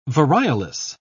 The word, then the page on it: variolous: British English